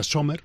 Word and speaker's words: Sommer: Llama mucho la atención que de nuevo un central, como es Sommer, o Comer, como lo quieras llamar, a nosotros en Valencia nos han dicho que se pronuncia Sommer,